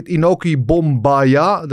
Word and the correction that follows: Bombaye: Dat is nu... – Bombaye. – Bombaye, ja, een memorial voor Inoki natuurlijk.